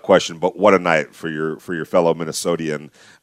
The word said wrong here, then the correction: Minnesotan: That's how you say it, right? Minnesotian? Am I right? – Minnesotan, no, yes, there you go.